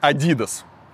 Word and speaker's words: Adidas: Вообще правильно говорить Adidas. Adidas, вот, если вы хотите быть правильным, да.